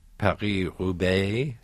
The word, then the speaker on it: Paris-Roubaix: Well, I ask because wasn't your favorite race each year the Paris-Roubaix? – Yes. – Is that how you pronounce it? – Yes.